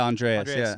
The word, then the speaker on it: Andreas: Did I pronounce it right? – Yeah, Andreas.